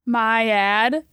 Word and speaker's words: MIAD: MIAD rhymes with rad and sad.